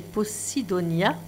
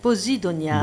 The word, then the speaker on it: Posidonia: projet Posidonia, c'est comme ça qu'on prononce. – Posidonia.